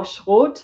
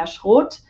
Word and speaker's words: Schroth: So Schroth, or Schroth, how we say in German, was developed by a lady called Katharina Schroth. And you know, I'm German, so I'm saying it in the German pronunciation. However, the English or Americans, they usually say Schroth.